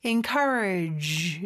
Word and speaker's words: encourage: 물론 여기는 G라고 쓰여져 있긴 하지만 약간 프랑스어 발음처럼 Z 이렇게 좀 네, 고급스럽게 발음해 주시면 좋을 것 같아요. encourage 이렇게 그 다음에 me 라고 하시면 돼요.